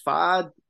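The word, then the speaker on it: Pfaadt: And you know, the guy they got going for the Diamondbacks tonight, I believe it's pronounced Fod.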